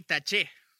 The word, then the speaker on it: Taché: Taché, como si tú estuvieras tachando algo en un papel, no sé. Jeremy Taché. Jeremy Taché es como se pronuncia. De hecho, él en su Twitter creo que tiene una E en la... un acento en la E, mejor dicho.